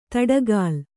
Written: ದಾಟುಗಾಲು

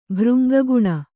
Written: ಭೃಂಗಗುಣ

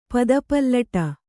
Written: ಪಾದಪಲ್ಲಟ

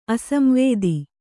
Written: ಅಸಂವೇದಿ

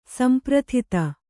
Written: ಸಂಪ್ರಥಿತ

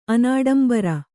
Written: ಅನಾಡಂಬರ

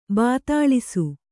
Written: ಭೂತೊಳಸಿ

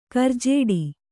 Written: ಗೌರಿಜಡೆ

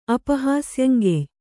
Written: ಅಪಹಾಸ್ಯಂಗೆಯ್

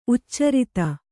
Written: ಉಚ್ಚಾರಿತ

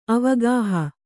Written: ಅವಗಾಹ

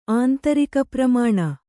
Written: ಆಂತರಿಕಪ್ರಮಾಣ

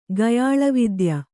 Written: ಗಯಾಳವಿದ್ಯ

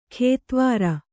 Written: ಕೆತ್ವಾರಿ

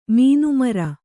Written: ಮೀನುಮರ

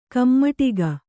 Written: ಕೈಮಡಗು

